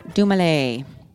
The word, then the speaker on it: Dumile: His character, MF Doom, based on the comic book character Dr. Doom, is also a play on his name, pronounced Dumale.